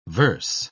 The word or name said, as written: verse